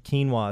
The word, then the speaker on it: quinoa: So other ideas, quinoa. If anyone has ever heard of quinoa, it's spelled Q-U-I-N-O-A.